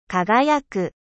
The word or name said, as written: 輝く